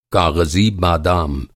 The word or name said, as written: काग़ज़ी